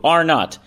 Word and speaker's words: R-naught: known as R-naught. You know, kind of like you're in an argument with your sibling. R2, R-naught. That's how it's pronounced.